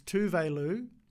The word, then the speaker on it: Tove Lo: She's like, no, you might be butchering it. It's Tove Lo, but I can't blame her.